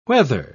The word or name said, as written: whether